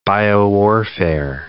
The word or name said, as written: biowarfare